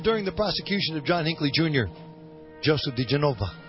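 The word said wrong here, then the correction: diGenova: Did I say that name correctly, Joseph? I got an Italian family, and yet I cannot pronounce the name. – It's DeGeneva. – DeGeneva. – Yep. – Joseph DeGeneva.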